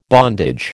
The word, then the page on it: bondage: American English